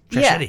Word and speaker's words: Trashetti: Like machete, yeah. Yeah. That's how you explained it to me. Trashetti, yeah. Like machete.